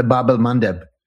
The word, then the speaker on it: Bab el-Mandeb: Bab el-Mandeb, I'm sure I'm butchering the pronunciation. – No, that's right, the Bab el-Mandeb.